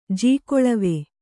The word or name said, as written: ಜೀಕೊಳವಿ